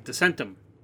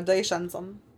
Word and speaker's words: Descensum: Yeah, I can never pronounce it. It's like Descentum. – No, Descensum.